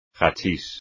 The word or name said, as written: hachís